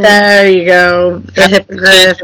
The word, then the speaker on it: hippogriff: and he talked bad to the hippogriff.